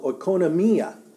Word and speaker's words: oikonomia: And it's called oikonomia. Oikonomia. And I could spell that for you, I think. It's O, let me put it on the board. O-I-K-O-N-O-M-I-A. Oikonomia. Oikonomia.